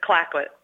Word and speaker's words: Clayoquot: Is that how you say it? Clayoquot Sound? British Columbia. Say it. – It's Clayoquot.